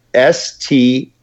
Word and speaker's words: Stribog: It's the Grand Power, Stribog. – Okay, Stribog. – Yep. – Stribog.